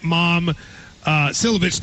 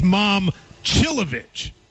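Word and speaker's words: Momcilovic: One person writes, Matt, I want to get Mom Silovich to come to Kentucky, but that's not how you say his name. It's Mom Chilovich.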